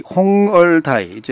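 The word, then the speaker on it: 홍얼다이 (紅二代): 자, 이 홍얼다이 이제 한국식 발음으로 하면 이제 홍이대라는 그 발음이 되는데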